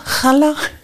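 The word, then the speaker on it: Challa: Und dann habe ich den halt drauf gezeigt und dann leuchteten ihre Augen und dann sagte sie, ah, Chala. Also, es wird anders ausgesprochen, als ich dachte.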